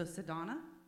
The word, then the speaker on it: Sadona: Otherwise, I am Sadona Mondabough. – Ooh. Okay. Well, now remind me, so now I won't say it wrong. – Oh, okay. So Sadona? – Yes. – Mondabough.